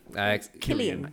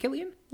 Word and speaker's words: Cillian: I don't know how to say his name. – Cillian. – Is it Cillian? – Yeah.